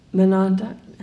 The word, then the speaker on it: Monadnock: Wells writes of just one hike he took to Mount Monadnock.